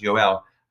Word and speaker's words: Joel: And for this guy, Yoel, spelled Joel, J-O-E-L, but it's pronounced Yoel, congratulations to him.